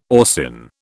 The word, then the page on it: orcin: British English